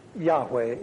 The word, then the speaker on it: Yahweh: Yahweh is the more likely pronunciation of this word.